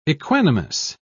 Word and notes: equanimous: British English

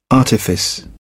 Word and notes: artifice: British English

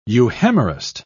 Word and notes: euhemerist: British English

